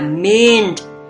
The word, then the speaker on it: mint: Cô bé, tiếng Anh của nó là mint, phát âm là mint, M-I-N-T, mint.